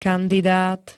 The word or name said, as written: kandidát